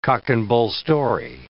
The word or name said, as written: cock-and-bull story